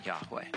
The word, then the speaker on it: Yahweh: In the Hebrew Bible, God's name, spelled with four Hebrew letters, Yod, Hey, Vav, Hey, and the most ancient pronunciation that we're pretty sure we can recover of it would have been Yahweh.